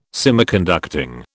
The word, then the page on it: Semiconducting: British English